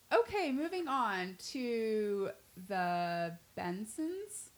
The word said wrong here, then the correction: Bengsons: Yeah, with the G in there. – I wasn't sure if the G was silent.